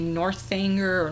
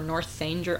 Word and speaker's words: Northanger: Next will be Northanger or Northanger. I better look up the pronunciation of that. I think the H is silent. I think it's Northanger Abbey.